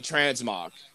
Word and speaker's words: transmog: And then like the transmog. I mean, I'm not trans. What did I say? Am I saying that right? Transmog? Yeah, yeah.